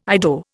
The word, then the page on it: Ido: Indian